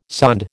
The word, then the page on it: sonde: American English